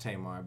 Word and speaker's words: Tamar: With Tamar Braxton. – Oh. – Her name is pronounced Tamar, but okay.